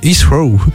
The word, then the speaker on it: Heathrow: Londres Heathrow.